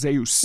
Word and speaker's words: Zeus: I believe it's pronounced Zeus.